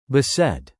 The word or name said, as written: beset